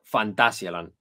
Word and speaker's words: Fantasialand: Fantasia que se pronuncia Fantasialand, no Fantasialand. Vamos a empezar por ahí. Venga, vamos a pronunciar bien las cosas. Fantasialand.